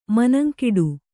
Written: ಮನಂಗಿಡು

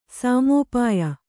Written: ಸಾಮೋಪಾಯ

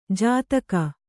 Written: ಜತಿಗೆ

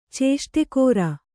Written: ಚೇಷ್ಟೆಗಾರ

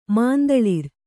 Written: ಮುಂತಳರ್

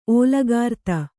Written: ಓಲುಗಾರ್ತಿ

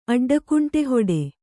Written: ಅಡ್ಡಕುಂಟೆಹೊಡೆ

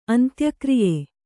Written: ಅಂತ್ಯಕ್ರಿಯೆ

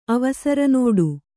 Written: ಅವಸರನೋಡು

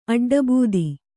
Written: ಅಡ್ಡಬೂದಿ

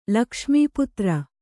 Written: ಲಕ್ಷ್ಮೀಪುತ್ರ

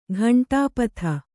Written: ಘಂಟಾಪಥ

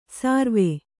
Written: ಸರ್ವೆ

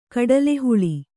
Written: ಕಡಲೆಹುಳಿ

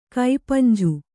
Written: ಕಪಿಂಜ

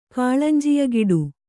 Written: ಕಾಳಂಜಿಯಗಿಡು

